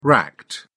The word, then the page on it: racked: British English